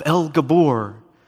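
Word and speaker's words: El Gibor: The one referred to as Mighty God was God himself, El Gabor, is how you say it in Hebrew, the Mighty God.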